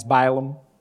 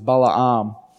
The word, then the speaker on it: Balaam: Balaam, if you want to get real ocker about it, because we're talking about being Australians, he's Balaam, old Balaam, but the way his name is pronounced is Balaam.